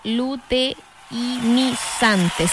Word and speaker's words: luteinizante: ¿Luteinizante? ¿Lo dije bien o no? A ver, déjame revisar. Luteinizante, sí, la LH.